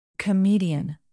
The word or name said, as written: comedian